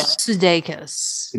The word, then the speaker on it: Sudeikis: Sudeikis? – Yeah, Sudeikis.